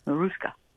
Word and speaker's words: Mariska: It's pronounced, according to Joanne, Mariska.